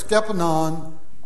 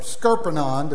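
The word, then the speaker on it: scuppernong: Skepanon or Skerpanon, depending on how you pronounce it. He always called them Skepanon, the Tennessee pronunciation.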